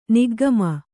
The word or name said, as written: ನಿಗ್ಗಮ